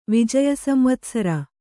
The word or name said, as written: ವಿಜಯಸಂವತ್ಸರ